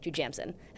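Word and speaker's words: Jujamcyn: The Niederlander and the Jujamson theaters. Jujamson. That's how you say it. Jujamson.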